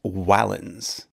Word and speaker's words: valens: Sometimes it was a vowel, like in Julius, but sometimes it was a consonant, like in the word valens, which means strong, although the Romans would have pronounced it closer to valens.